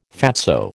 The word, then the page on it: fatso: American English